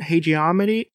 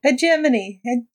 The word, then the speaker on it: hegemony: Why do owner keeps on hegemony? I keep on saying it wrong. – Hegemony. There you go.